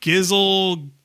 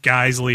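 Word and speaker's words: Geissele: So I looked up interview with Mr. Geisley, and he said it's pronounced Geisley, and he spelled it out Guislee.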